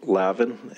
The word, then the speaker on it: Lavin: My name is Colonel Steve Lavin. It's spelled L-A-V-I-N, and I'm the Chief Administrator of the Montana Highway Patrol.